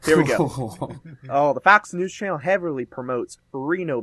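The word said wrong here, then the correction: RINO: backers. – It's Rhino.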